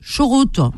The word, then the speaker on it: charrette: Non, c'est parce que les ch'tis, le A, ils le prononcent O. Alors, donc, ils disent pas... Ah oui, ils disent pas charrette, ils disent chorote.